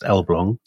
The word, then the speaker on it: Elbląg: It's spelt Elblag, but it's pronounced Elbląg, so my Polish contacts tell me.